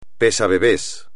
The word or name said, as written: pesabebés